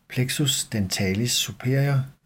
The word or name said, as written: plexus dentalis superior